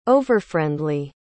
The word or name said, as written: overfriendly